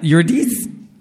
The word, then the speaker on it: Eurydice: So this is the telling of Orpheus and I cannot pronounce Eurydice.